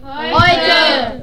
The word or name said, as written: heute